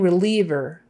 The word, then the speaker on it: reliever: pain reliever, that I is E, you say it like an E, reliever, reliever, reliever, pain reliever, or pain reliever.